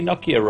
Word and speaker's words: Nokia: Like, hey, you say Nokia wrong. We actually do say Nokia wrong. We should say Nokia because that's actually how they say it in Finland.